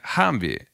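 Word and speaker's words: HMMWV: и сокращенно произносится как «Хамви».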